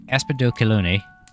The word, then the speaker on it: Aspidochelone: Well, with some help from Loki, I have figured out that it is pronounced Aspidochelone.